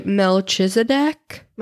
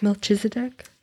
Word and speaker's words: Melchizedek: I would say Melchizedek. – Melchizedek? – Melchizedek.